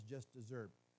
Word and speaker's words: desert: He would say, no doubt, Mr. Burns would say, that that officer got his just dessert. And we've heard of that phrase before, and it is spelled like you would spell the word desert, but it sounds like the word dessert.